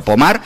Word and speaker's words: Pomar: Si las buscáis, se escribe Pomar, como si fuera en castellano, pero Pomar, pues allí en Francia, hablamos de unas imágenes que podían pasar.